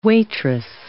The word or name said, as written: waitress